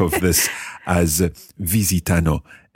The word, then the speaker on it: visitano: But just watch your accent on the third person plural. Visitano. – Visitano. – It is a very tricky sort of pattern of sounds to get in there.